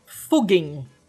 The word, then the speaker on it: Fugging: Fugging. Com dois G's. Como é que escreve? – F-U-G-G-I-N-G que é como é, é, é a pronúncia do nome da aldeia de acordo com o pessoal que mora lá.